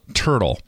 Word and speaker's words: Tertill: He is the CEO of a company called Franklin Robotics in Boston, Massachusetts, and they have invented a product called Turtle. It's spelled T-E-R-T-I-L-L, but it is a robot that weeds your garden for you.